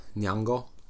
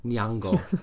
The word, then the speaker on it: Nyong'o: Yeah, Nyong'o.